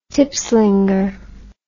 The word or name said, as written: tipslinger